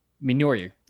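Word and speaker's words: Mjölnir: Mjolnir. – It comes with a Mjolnir replica.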